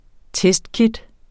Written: testkit

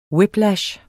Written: whiplash

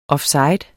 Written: offside

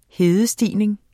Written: hedestigning

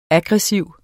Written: aggressiv

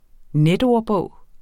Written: netordbog

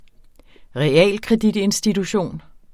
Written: realkreditinstitution

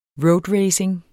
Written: roadracing

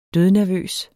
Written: dødnervøs

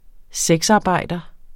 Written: sexarbejder

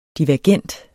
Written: divergent

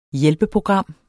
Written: hjælpeprogram

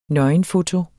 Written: nøgenfoto

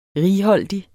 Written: righoldig

